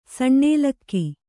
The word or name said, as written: ಸಣ್ಣೇಲಕ್ಕಿ